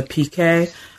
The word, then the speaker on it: Piquet: Um, chapter three is called "Life on the Piquet," Um, am I pronouncing that correctly? – Yes, you are.